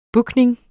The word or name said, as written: booking